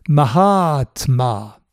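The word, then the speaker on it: Mahatma (महात्मा): Celui qui est satisfait, c'est le nom qui suit ma'atma, syllabe courte suivie de deux syllabes longues. Mahatma.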